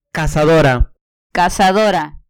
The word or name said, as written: cazadora